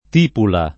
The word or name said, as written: tipula